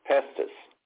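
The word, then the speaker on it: pistis: So now that word faith in the Greek is called pestes, pronounced pestis.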